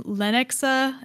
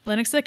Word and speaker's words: Lenexa: In February 2015, we know it was used in Lenexa. I'm sorry, I'm saying that wrong. Lenexa, Kansas. Not far from where Kelsey had grown up.